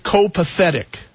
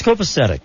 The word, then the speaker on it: copacetic: Or is it copathetic? – No, it's copacetic.